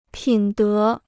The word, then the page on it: 品德: pǐn dé